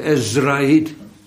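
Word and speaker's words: Ezrahiet: Nog een keer lezen, Ezrahit, dat is de naam Zera. Maar dan op Spaanse wijze uitgesproken. Van España en Estrada en hoe dat maar heet. Dat weet u toch, hè? Dat is... Eetje ervoor, klinkertje ervoor, maar verder maakt het niks uit.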